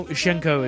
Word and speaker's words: Hsien-Ko: Uh, this is a bit of a tangent, but I'm, I've never been able to figure out how you're actually supposed to pronounce her name because I've heard it about five different ways. Well, Hsien-Ko is correct. That's what everyone says. well, I say it is the, is the correct way. – It's what? the Chinese pronunciation, so.